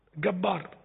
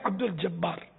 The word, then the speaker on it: عبد الجبار: تو اس کے اس طرز کلام سے پتا چلا کہ یہ لوگ جیم کو گاف بولتے ہیں مصر والے. بعد میں غالباً یہ تصدیق ہوئی کہ ہاں یہ بیچارے وہ جیم کے تلفظ سے محروم ہیں، صحیح مخرج سے محروم ہیں. گبار ہے عبد الجبار.